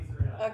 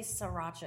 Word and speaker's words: Sriracha: I don't have any sriracha. – Okay. He always corrects me when I say sriracha. He's like, it doesn't say sriracha. It's Sriracha. – Yeah, because it's SR.